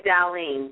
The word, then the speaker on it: Darlene: Hi, Darlene, D-A-R-L-E-N-E.